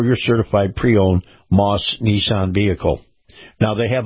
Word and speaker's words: Maus: Now, this portion of the program brought to you by our friends at Moss, that's spelled M-A-U-S, Family Automotive, and all those Moss Nissan dealerships.